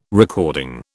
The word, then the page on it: recording: British English